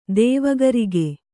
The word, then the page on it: ತ್ವಗ್ರೋಗ: tvagrōga